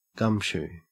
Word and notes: gumshoe: Australian English

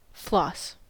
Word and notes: floss: American English

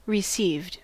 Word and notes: received: American English

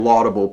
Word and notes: laudable: American English

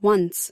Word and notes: once: American English

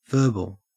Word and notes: verbal: Australian English

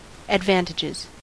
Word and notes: advantages: American English

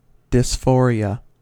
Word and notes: dysphoria: American English

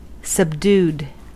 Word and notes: subdued: American English

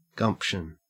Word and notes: gumption: Australian English

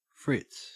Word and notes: fritz: Australian English

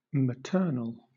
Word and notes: maternal: British English

